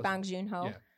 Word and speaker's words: Bong Joon-ho: Bong Joon-ho? – Yeah, close. Bong Joon-ho.